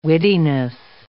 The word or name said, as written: wittiness